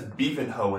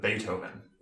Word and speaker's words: Beethoven: Taylor says Beavenhoe instead of Beethoven. – Beavenhoe? – Yes. – Where'd the T go? – Where did half the letters go?